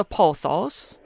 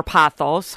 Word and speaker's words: pothos: And the ones you want to try are the pothos, or pothos, however you want to pronounce it. – How's that spelled? – P-O-T-H-O-S.